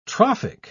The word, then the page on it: trophic: British English